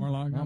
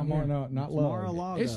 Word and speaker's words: Mar-a-Lago: How do you say it? Is it Mar-a-Lago? – Mar-a-Lago. – Mar-a-Lago.